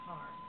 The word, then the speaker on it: Qatar: And I know I live there, and that's how you pronounce it, Qatar.